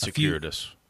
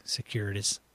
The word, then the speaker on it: circuitous: That's circuitous, by the way. – Thank you. Circuitous. – There you are. – Oh, my God. It trips me up, but then when I hear it pronounced, I'm like, okay.